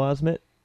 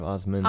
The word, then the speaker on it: Osment: Haley Joel Osment? – Haley Joel Osment? – Brady? Rolling? – It is pronounced Haley. – All right, from The Sixth Sense, and... – it's Osment instead of Osmond.